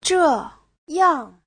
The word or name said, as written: zhe4yang4